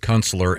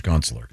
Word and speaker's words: Kunstler: And he does pronounce it Kunstler. And if you pronounce it. – Well, of course he does. – He made a real. He would always say if someone pronounced it William Kunstler, he'd make a real big deal. No, it's Kunstler.